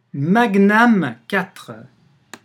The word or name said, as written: magnam